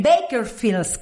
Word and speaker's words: Bakersfield: Bakersfield, California. – Bakersfield. – ¿Viste? Lo dije bien.